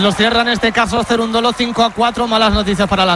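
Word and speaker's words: Cerúndolo: Este juego, aunque lo haya ganado Cerundolo, que lleva acento en la U y tal,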